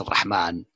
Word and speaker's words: ar-Rahman: Ar-Rahman, as I said, the writing is clear, is without Alif, Rahman, but it's not pronounced Ar-Rahman ever, it's Ar-Rahman.